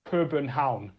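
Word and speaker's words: København: Copenhagen. It's because the U is a sound that doesn't exist in English. – Okay, and say it one more time slowly for me. – Copenhagen.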